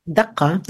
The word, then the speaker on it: Dhaka: Dhaka, yes. – Okay. Mm-hmm. And it's like a Dhaka is like a beat, right? – Yes, exactly.